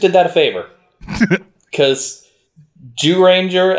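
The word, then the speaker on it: Zyuranger: Oh, it's spelled Z-Y-U, but in Japanese, it's Jew. So, Jew Ranger.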